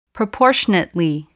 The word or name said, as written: proportionately